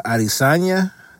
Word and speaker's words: Adesanya: Uh, but I did watch the last event between the cat, the young cat, uh, Ishmael uh, Adesanya. that's how you say his name.